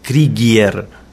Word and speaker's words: Krygier: Entonces, pregunté a una polaca pura cómo se pronuncia mi apellido en polaco, y es Krieger, sería. – Mm. – Pero bueno, estamos aquí. Yo siempre dije Krieger hasta que tuve que empezar a usarlo artísticamente.